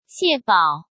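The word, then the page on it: Xie Bao: Chinese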